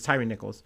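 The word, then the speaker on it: Tyre Nichols: From CNN, Memphis releases video showing police stop that led to Tyrese Nicholas' death. And then there's also a little bit of a bio for Tyree Nichols. Sorry, I'm saying his name wrong. It's Tyree Nichols.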